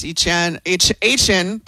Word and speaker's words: Etienne: Travis Etienne, that's how you say it.